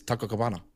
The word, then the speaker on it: Taco Cabana: Because that's how it's pronounced, dude. It's Taco Cabana.